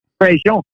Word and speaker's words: Prejean: How do you pronounce it perfectly? – Well, we kind of anglicize it a little bit. We'd say Prejean. – But how would you do it? – It would be Prejean.